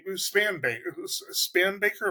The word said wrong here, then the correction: Spanberger: am I saying that right? What's her name? – Spanberger.